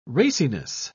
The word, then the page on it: raciness: British English